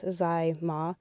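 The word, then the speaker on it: Tzi Ma: So the last one that we're going to talk about is the Chinese gentleman, Mr. I believe it's pronounced Zai Ma. It's spelled TZI and the next name MA.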